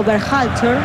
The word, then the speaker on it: Berhalter: Dígalo usted. – Berhalter. – ¿Y qué dije yo? – No, impecable.